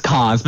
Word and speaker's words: Cahans: Almost. It's Cahans, but it looks like that.